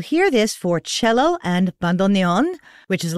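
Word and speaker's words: bandoneón: And a note on the pronunciation, I love this, of the word bandoneon. One of my music pals, Emmanuel Trifilio, is a professional bandoneonisto. And in order to make it easier for non-Spanish speakers to pronounce, he very graciously invites you to say bandoneon. Bandoneon. There you go.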